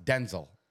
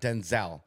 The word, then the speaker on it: Denzel: So Denzel, and I bet you guys didn't know his name's pronounced Denzel, not Denzel.